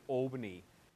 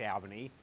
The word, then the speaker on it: Albany: And I don't know, in New South Wales, people pronounce it Albany, but it is, in fact, Albany.